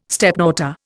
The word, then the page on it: stepdaughter: Indian